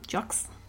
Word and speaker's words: Jocz: J-O-C-Z. How would you say that? Jux? – Sure.